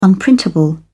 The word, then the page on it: unprintable: British English